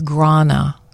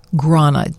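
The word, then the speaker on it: Gráinne: I'm going with Grana. Grana. G-R-A-I-N-N-E.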